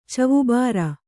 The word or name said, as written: ಚವುಬಾರ